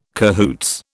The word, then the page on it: cahoots: British English